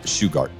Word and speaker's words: Shugart: What's the correct pronunciation of Shugart? – Shugart. – Shugart. David Shugart. – Sugar with a T on it.